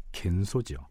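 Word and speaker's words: 겐소지요 (玄蘇): 자, 일단 통신사의 상사인 황윤길은 일본 사신이 묵고 있는 객관인 동평관으로 가서 승려 현소를 만납니다. 일본 발음으로는 겐소지요 일본에 대한 사전 정보를 얻기 위해서였습니다.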